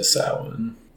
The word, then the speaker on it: Samhain: Unofficially, officially kicking off the countdown to Samhain. – That's right. You actually pronounced it correctly.